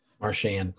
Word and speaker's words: Marchand: You have Brad, is it Marchand? – Marchand.